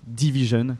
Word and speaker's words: DVSN: Avec un groupe qui s'appelle Division, qui s'écrit D-V-S-N.